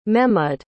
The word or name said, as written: mehmud